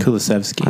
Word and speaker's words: Kulusevski: But I wanted to get our guy Kulusevski. How do you pronounce him? – Kulusevski.